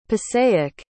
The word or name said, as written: passaic